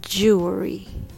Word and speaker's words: jewelry: The first part is Jew. Jew. Like Jewish people. Jew. And then it's followed by L sound, so it's jewel. Jewel. It sounds like cool, tool fool, jewel. The last syllable is re. That's er and e put together re, re, re. Okay? Let's put them together very slowly. Jewel. jewelry.